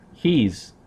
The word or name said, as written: he's